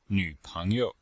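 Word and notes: nvpengyou: nv3peng2you5

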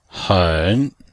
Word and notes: hen3: pinyin

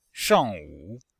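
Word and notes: shangwu: shang4wu3

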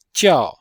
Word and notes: jiao: jiao4

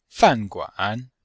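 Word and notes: fanguan: fan4guan3

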